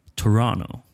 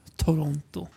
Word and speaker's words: Toronto: Man, man, man ska inte uttala T. – Nej, det andra tet. Men jag tänker ändå säga Toronto.